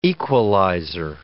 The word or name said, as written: equalizer